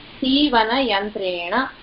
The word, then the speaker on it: सीवनयन्त्रेण: सीवनयन्त्रेण, अस्तु महोदय, अस्तु भगिनि, णत्वं णत्वमस्ति, रकार परं नकार अस्ति, बाधा नास्ति, सीवनयन्त्रेण इति पदमस्ति. – उत्तमम्, इत्युक्ते अत्र णत्वसहितम् उच्चारणीयं, यन्त्रेण.